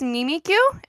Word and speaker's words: Mimikyu: What is Mimikyu? Am I saying that right? – Correct. Yes. – Yes. – You said it right.